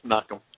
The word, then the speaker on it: Menachem: If you take it the Hebrew way, yeah, it's Menachem.